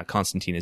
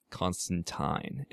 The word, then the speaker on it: Constantine: They're always reminding you that his last name actually rhymes with Valentine, and nobody ever actually remembers. So, like, I would like to write Constantine, and, you know, that's not going to happen.